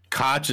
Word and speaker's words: Koch: Koch is pronounced like Coke. How the fuck does that work? – It's apparently German or something.